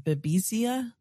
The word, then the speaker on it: Babesia: Babesia? – Yes.